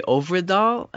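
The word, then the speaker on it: Øvredal: It's directed by the Swedish director by the name of Andre Ovredal, I believe is how you pronounce it.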